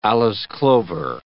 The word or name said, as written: Alyce clover